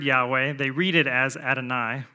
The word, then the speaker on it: Yahweh: You've probably heard this before, that that comes from his name, Yahweh, and that's kind of how you would pronounce it if you were reading it directly.